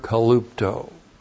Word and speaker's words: kalupto: And then the verb kalupto. K-A-L-U-P-T, long O. Kalupto.